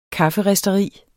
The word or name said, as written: kafferisteri